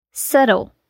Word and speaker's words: subtle: Quando ela aparece antes da letra T, também fica silenciosa, como em doubt, dúvida, debt, dívida e subtle, sutil.